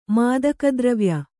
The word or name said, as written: ಮಾದಕದ್ರವ್ಯ